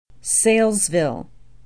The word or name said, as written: Saylesville